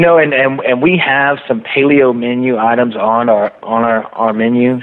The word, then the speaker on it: Paleo: I saw an article that mentioned that corner table is tied into the paleo diet. Did I say that right? – Paleo, sir.